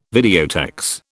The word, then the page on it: videotex: British English